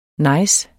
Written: nice

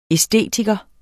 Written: æstetiker

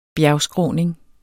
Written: bjergskråning

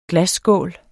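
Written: glasskåle

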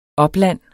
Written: OPLANDS

